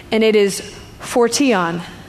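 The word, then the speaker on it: phortion (φορτίον): Phortion. That's actually what it says. It looks like portion, but it's phortion.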